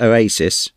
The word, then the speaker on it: Owasys: The phone is made by a Spanish company, spelt O-W-A-S-Y-S, pronounced Oasis, who hope to have their 22C handset on the UK market at the beginning of 2004.